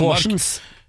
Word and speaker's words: Horsens: Tirsdag fikk Harald Halseth, 64, fra Kabelvåg, regning fra One Park AS for en feilparkering i Horens, eller Horens eventuelt da, i Danmark. – Horsens. – Ja, det, her står det uten S. – Ja, jeg tror det uttales Horsens.